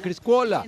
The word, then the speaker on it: Criscuola: Criscuola. – O si tuviera pañi diría Criscuola tal vez. – No, ¿cómo se escribe? – Criscuola se escribe. – Criscuola. – Criscuola.